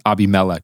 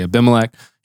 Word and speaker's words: Abimelech: It can be pronounced Abimelech, but I'm going to say Abimelech.